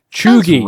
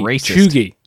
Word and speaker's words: cheugy: And then another one is Chiyugi. – What? – I'm probably butchering that word, but it doesn't look right. C-H-E-U-G-Y. Chugi. Chugi. That's what it is.